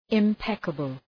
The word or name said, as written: impeccable